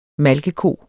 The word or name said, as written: malkeko